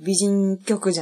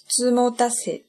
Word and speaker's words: 美人局: 可能自然的就会讲应该是读作“ 美人局” じゃないんですかね。実は美人局じゃなくて、つもたせって読むんです。